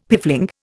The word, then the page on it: piffling: Indian